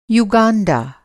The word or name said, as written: यूगाँडा